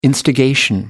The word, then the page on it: instigation: British English